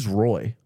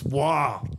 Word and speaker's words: Roy: And I remember I even had a Patrick Waugh jersey at one point, and I remember getting so mad at people for being like, who's Roy? – How dare you? – How dare you? It's pronounced Wah.